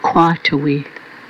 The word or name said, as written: kwotawi